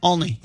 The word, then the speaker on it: Olney: Okay, so y'all pronounce the O like it's an A. – Yeah, it's Olney. – Olney. – Like Olney. – Olney. – Olney. Olney. – Olney. – Olney. – Why are you adding a W? – Because it starts with an O. – If I was typing it out on a pronunciation guide, it would say all, A-L-L, dash, knee.